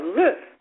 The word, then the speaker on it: l'œuf: In France, where tennis became popular, the round zero on the scoreboard looked like an egg and was called l'œuf, which is French for the egg. When tennis was introduced in the U.S., Americans naturally mispronounced it love.